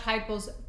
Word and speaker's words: Heupel: Heupel's first SEC win. Did I say his name right? – You said Josh Heupel's name right, maybe the first time in your life.